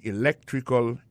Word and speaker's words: electrical: I majored in electrical engineering. Electrical engineering. Ça veut dire accent tombé dans electrical là, c'est sur deuxième syllabe là. Electrical engineering. C'est sur deuxième syllabe là. Elect là. Plus appuyé.